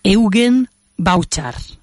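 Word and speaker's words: Evgen Bavčar: lo, busqué para ver cómo se pronuncia y creo que se dice así, Eugen Bauchar. ¿vale? Pero se escribe E V G E N B A V C A R y lo digo porque eh, he tomado algunas de las fotografías que vienen en su página web, que es su nombre, ¿vale?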